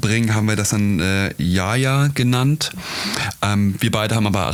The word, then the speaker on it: Yahya: Buchstabiert Y-A, H-Y-A. Und das ist eigentlich, ursprünglich ist das ein arabischer Vorname. Ja, ein arabischer Vorname, der wird eigentlich Yahya ausgesprochen und übersetzt bedeutet das am Leben sein, lebendig sein, am Leben teilnehmen und das Leben zu zelebrieren.